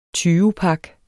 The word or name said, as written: tyvepak